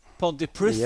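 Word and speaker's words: Pontypridd: Pontyprid. Pontyprith. – Pontyprith, yeah, ja. Det ble ja. litt sånn eh, lesping på slutten.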